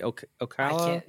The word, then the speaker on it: Ocala: It's O-C-A-L-A Ocala.